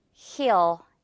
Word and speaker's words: he'll: 당연히 he'll. 그렇죠. 이제 여러분 맞추셨죠? He'll. 만약에 여자라면 she'll 되겠죠. She will 아니고 she'll. 그리고 he will 아니고 he'll.